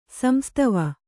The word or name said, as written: ಸಂಸ್ತಾವ